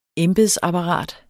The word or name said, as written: embedsapparat